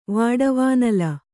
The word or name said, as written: ವಾಡವಾನಲ